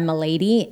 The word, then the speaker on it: Mileydy: It's very complicated, but it's colored by Milady, and that's M-I-L-E-Y-D-Y. I might change the name soon, but M-I-L-E-Y-D-Y.